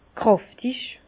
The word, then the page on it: kräftig: Bas-Rhin; Herrlisheim